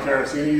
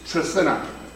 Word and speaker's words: Teresina: Matter of fact, you don't say it like Teresina, you say it like Tresina. You don't say the first E.